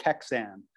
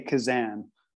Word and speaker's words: Keczan: So it looks like Kexan, but I say Kazan, K-E-C-Z-A-N.